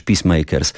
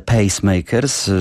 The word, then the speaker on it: Pacemakers: Mówi: no dobrze, no to w takim razie powiedz mi, jak się wymawia nazwę tego zespołu, ale jak powiesz Peacemakers, to cię zabiję. No więc powiedziałem: nie, nie, nie, ja wiem, że to jest Jerry and the Pacemakers, że jakoś starałem się to zapamiętać od zawsze, w związku z tym błędu raczej nie popełnię.